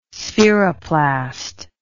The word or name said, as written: spheroplast